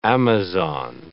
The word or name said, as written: Amazon